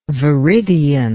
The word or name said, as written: veridian